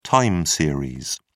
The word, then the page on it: time series: British English